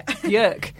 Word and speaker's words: Björk: Björk.